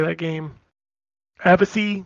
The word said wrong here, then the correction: Avicii: Is that how you say it? – Avicii. – Battle Hunters. Avicii. There you go.